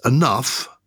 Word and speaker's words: enough: As a child, I could never work this out, why dough, D-O-U-G-H, is pronounced dough, whereas enough, the same ending, O-U-G-H, you're pronouncing the off.